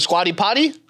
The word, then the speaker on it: Squatty Potty: Now, my favorite in-home appliance now is a Scooty Pooty. You know what this is? – You mean the squatty potty? – The squatty potty. – Yeah. Am I saying it wrong? Oh, yeah.